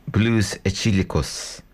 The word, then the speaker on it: Blues Etílicos: yes, that was actually it's pronounced Blues Etílicos by,